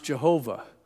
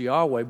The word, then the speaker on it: Yahweh: And they used to think, well, it's probably pronounced Jehovah. And now we think it's probably more accurately pronounced Yahweh, but we don't know.